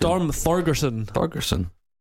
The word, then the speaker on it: Thorgerson: How'd you say his name? Is it Storm Thorsten? – Storm Thorgerson.